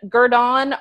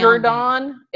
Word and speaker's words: Gurdon: Okay, spell that town. – Gurdon, it's G-U-R-D-O-N. – Gurdon. I say Gurdon.